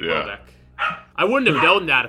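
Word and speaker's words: Houellebecq: Wait, is that how you say his name? – Welbeck? – Yeah. – That's how you say it? – Yeah, how did you say it? – I was saying, like, no. Hollenbeck. – It's just Welbeck.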